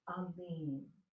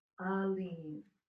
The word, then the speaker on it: Alim: But this word is Aleem. Aleem. It's often transliterated or spelled A-L-I-M, but it's pronounced A-L-E-E-M, long E-E.